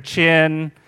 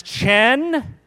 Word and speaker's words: Chen: I hear Chin. I hear Chan. A woman read my name off a list. I was at an appointment. She read my name off a list. She pronounced it Stanley Chang. Chang. She read it as Chang. I said, no, ma'am, it's pronounced Chen, the G is silent, and also invisible.